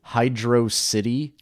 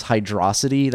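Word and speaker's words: Hydrocity: There's a stage in the third one that's spelled Hydro City, but I think the pronunciation is Hydrosity. Sounds more, but I know there's like a flame war on the internet about, fuck you, it's Hydrosity. No, fuck you, it's Hydro City.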